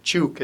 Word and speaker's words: Chuuk: Chuuk as it's pronounced now,